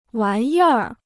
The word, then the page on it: 玩意儿: wán yì r